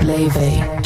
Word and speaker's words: Laufey: Laufey Lean Bing John's daughter, known simply as Laufey, pronounced Leve,